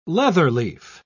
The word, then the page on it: leatherleaf: British English